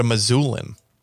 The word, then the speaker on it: Missoulian: Is that how you say it? Missoulian? Or is it a Missoulian? – It's Missoulian.